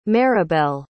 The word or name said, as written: maribelle